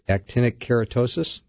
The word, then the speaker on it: actinic keratosis: and then he said I had actinic keratosis.